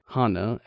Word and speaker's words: hana: Tengu characters had a long nose, and the Japanese word for nose is pronounced the same as the Japanese word for flower. Hana, as in Hanafuda.